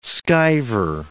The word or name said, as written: skiver